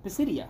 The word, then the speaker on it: Pisidia: Paul and Barnabas moved inland to Antioch in the province of Pisidia. I believe I pronounced that correctly.